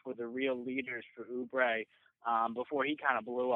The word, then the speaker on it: Oubre: It's Oubre, right? Am I saying that right? – Yeah.